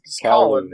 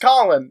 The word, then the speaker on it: Colin: Yeah, it's Colin. It's just Colin. It's literally just Colin.